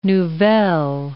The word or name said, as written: nouvelle